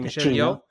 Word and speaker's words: Michelle Yeoh: Sí, Michelle Yeoh.